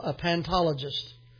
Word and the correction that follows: paleontologist: People that excavate. Did I say that right? Paleontologist. Thank you.